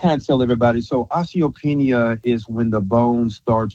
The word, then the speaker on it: osteopenia: Miss Jackson wanted to know, can you share a little bit or do you know a light on osteopenia? Is that, am I saying that right? O-S-T-E-O-P-E-N-I-A. Osteopenia? – Yes.